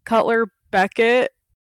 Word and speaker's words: Cutler Beckett: I was going to say, I feel like Cutler Beckett... Did I just say his name correctly? – Yeah.